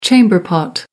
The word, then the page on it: chamber pot: American English